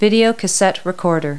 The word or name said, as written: vcr